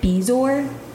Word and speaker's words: bezoar: But Lisa went to the doctor, and the doctor showed her this thing that is called a bezoar. Do you know what a bezoar is? – Are you pronouncing it right? – Yeah, B-E-A-Z-O-R.